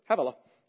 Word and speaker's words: Havilah: Havilah? Sure, Havilah.